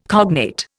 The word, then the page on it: cognate: Indian